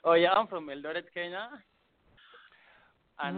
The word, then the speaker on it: Eldoret: Now, if I'm mispronouncing this, please help me out. Is it Eldoret, Kenya? – Yeah.